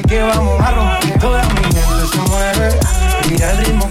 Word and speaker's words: Mi Gente: And I just learned the correct pronunciation. It's Mi Gente, the Henry Fong remix, no relation, J Balvin and Willie Williams.